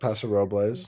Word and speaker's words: Paso Robles: Paso Robles.